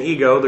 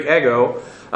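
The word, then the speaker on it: ego (ἐγώ): The English pronunciation, ego, the Greek, ego.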